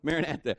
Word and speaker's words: Maranatha: But this was a greeting, and it was called Maranatha. Did I say that right? Close enough. Maranatha.